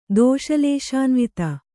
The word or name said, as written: ದೋಷಲೇಶಾನ್ವಿತ